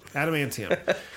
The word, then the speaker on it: Adamantium: Antium? – Adamantium. – Adamantium.